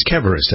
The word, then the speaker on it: Keverest: This is the American Family Farmer welcoming Lisa Keverst. It's Keverest. I got to make sure I pronounce the name correctly.